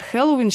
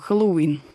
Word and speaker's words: Хэллоуин: Ну, а если взять наш словарь ударений русского языка, то вариант Хэллоуин считается допустимым, а вот все-таки ударение должно быть на последний слог. Хэллоуин.